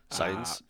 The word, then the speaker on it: Sainz: Pretty obvious he's never heard of Carlos Sainz. Am I saying it right? – Sainz.